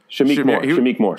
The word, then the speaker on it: Shameik Moore: Shameik Moore. – Shameik.